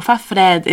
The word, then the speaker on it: fa fred: Or fa fred. It ends in a D, but again, it's a kind of soft D, which sometimes sounds like a T. Fa fred, fa fred. It depends how you say it.